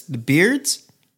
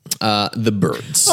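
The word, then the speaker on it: Byrds: You Ain't Going Nowhere by none other than the, how do you pronounce this? The Beards? – The Birds. – Oh, the Birds. I see. – Yes. That Y does throw a lot of people off.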